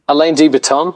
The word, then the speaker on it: Alain de Botton: Alain de Botton. – Alain de Botton.